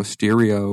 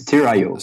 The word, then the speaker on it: Steerio: So, uh, what I you know you had mentioned Steerio, Stereo, or how do you pronounce that correctly? – It's Steerio. Steerio.